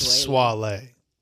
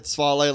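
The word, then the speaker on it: Swae Lee: He's got Caymans with Sway Lee? I always say it wrong. – It's pronounced Swalee. – Okay. But I said Swalee last time.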